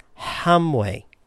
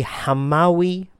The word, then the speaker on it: Hamawi: And I pronounced it based on what I'd seen in an English book as Hamwe. Well, I think that's actually the incorrect pronunciation. I was in Shuk Machane Yehuda, the market here in Yerushalayim, and there is a butcher shop called the Hamawi Brothers.